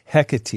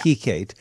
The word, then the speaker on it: Hekate: Yeah, it's spelled H-E-K-A-T-E. Hecate. – Correct. – Looks like yeah. – Hecate.